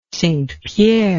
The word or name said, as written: St Pierre